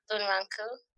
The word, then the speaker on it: Bunraku: but I don't care because I know it's Bunraku. – Yeah, Bunraku style. Yeah, you nailed it.